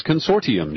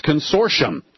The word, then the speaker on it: Consortium: Because for benefit of people who want to find your website, I often overpronounce it as Consortium News. And he said, no, it's Consortium, like S-H.